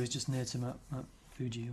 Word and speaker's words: Fuji: It's on the main train line between Tokyo and Kobe, and it's near Mount Fuji, or Fuji, as I was told to pronounce it, because they don't have the letter F.